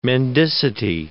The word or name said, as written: mendicity